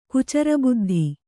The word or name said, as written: ಕುಚರಬುದ್ಧಿ